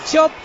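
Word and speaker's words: Čop: Cop se pronuncia Chop. – Chop. – Chop, vale, chop. Vale. Como la sardilla, chip y chop.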